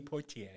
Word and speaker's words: Poitier: Sidney Poitier.